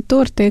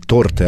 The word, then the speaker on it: торты: Сейчас у нас полноценная кондитерская, мы делаем торты, эклеры, разные безе, печенье. – Как я люблю, когда человек говорит правильно ударение – торты, а не торты. Торт, молодец.